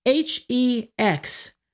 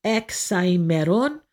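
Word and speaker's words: Hexaemeron: Hexaimeron. That is one word. It means 6 days in Greek. The hex, H-E-X. We don't really say the H part in Greek. It's Exaimeron.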